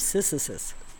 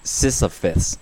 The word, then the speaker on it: Sisyphus: The myth of Sisyphus. – Sisyphus. – Sisyphus, okay.